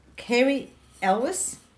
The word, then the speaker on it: Cary Elwes: It stars Cary Elwes.